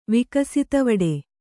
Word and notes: ವಿಕಸಿತವಡೆ: kannada

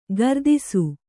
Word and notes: ಕೀರ್ತಿಸು: kīrtisu

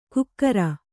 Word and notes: ಕುಕ್ಕರ: kukkara

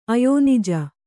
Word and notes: ಅಯೋನಿಜ: ayōnija; Kannada

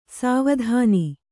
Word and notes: ಸುವಿಧಾನ: kannada

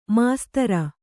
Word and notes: ಮಾಸ್ತರ: māstara

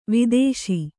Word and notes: ವಧಿಸು: Kannada

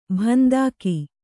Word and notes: ಭಂದಾಕಿ: bhandāki

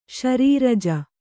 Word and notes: ಶರೀರಜ: śarīraja

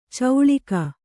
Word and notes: ಚೌಳಿಕ: cauḷika; Kannada

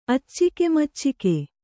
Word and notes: ಅಚ್ಚಿಕೆಮಚ್ಚಿಕೆ: kannada

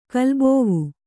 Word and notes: ಕಲ್ಬೋವು: Kannada

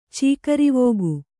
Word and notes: ಚಕ್ರವಾಕ: cakra vāka

